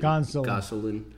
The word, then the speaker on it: Gonsolin: It wasn't looking great for them coming into the second one with Kershaw going down, but they have Gasolino going. I don't know how to say his name. – Gonsolin.